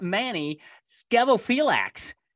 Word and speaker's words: Manny Skevofilax: Today I am talking with Manny Skevofilax.